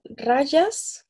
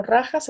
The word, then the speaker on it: rajas: tomamos lo suficiente de rayas, como o rajas, se pronuncia de cualquiera de las dos maneras, para estar con vitalidad, con entusiasmo, con alegría.